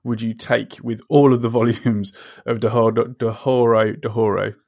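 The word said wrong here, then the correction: Dorohedoro: Doro Hedoro.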